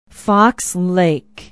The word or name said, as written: Fox Lake